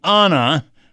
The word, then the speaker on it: Anna: The April 11th New Yorker featured an article calling Anna, her preferred pronunciation of A-N-N-A, Ferris, Hollywood's most original comic actress, and devoting a full 9 pages to her.